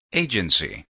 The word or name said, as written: agency